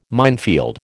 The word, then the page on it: minefield: American English